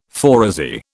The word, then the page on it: phoresy: British English